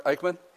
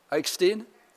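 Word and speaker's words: Eckstein: How many of you are familiar with Rabbi, honey, am I saying it right, Eichmann? Eichstein? Okay.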